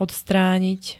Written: odstranit

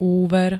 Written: úver

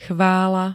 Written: CHVÁLA

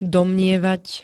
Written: domnievať

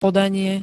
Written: podanie

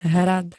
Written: hrad

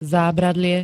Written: zábradlie

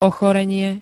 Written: ochorenie

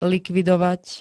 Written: likvidovať

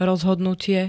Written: rozhodnutie

